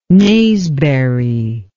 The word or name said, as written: naseberry